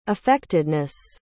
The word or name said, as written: affectedness